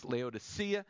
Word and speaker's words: Laodicea: Well, anyway, Colossae, Hierapolis, and a city called Laodicea, which is usually pronounced in English as Laodicea.